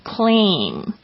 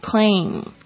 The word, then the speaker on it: claim: 好，所以呢这个提领处呢，这个当名词在这边 claim, 大家注意一下发音哦，是 claim, 有一个微笑的 a 的声音，加上你最后嘴巴要记得闭起来，但是不要的太大声， claim。